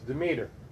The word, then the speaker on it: Demeter: I have such a hard time pronouncing words. Demeter. That's it, Demeter.